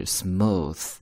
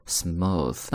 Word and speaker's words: smooth: 所以它有 th 这样的一个音，所以呢在咬舌头，所以发音就是 smooth， smooth。